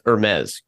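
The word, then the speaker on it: Hermès: It's Hermes, correct? Am I saying that correct? Yep. I should have figured this out before.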